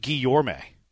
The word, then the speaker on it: Guillorme: Like, he only plays second, whereas Guillermo, it's actually pronounced Guillorme. Yes, I knew it was weird. – Really? – But, yeah, it's Guillorme.